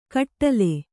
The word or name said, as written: ಕಟ್ಟೋಲೆ